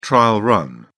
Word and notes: trial run: British English

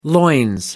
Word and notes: loins: British English

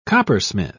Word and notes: coppersmith: British English

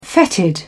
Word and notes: fetid: British English

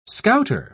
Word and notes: scouter: British English

